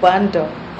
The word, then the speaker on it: Obando: Bando. O-B-A-N-D-O. Bulacan. B-U-L-A-C-A-N.